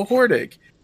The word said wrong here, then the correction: Hordak: Or Hordak.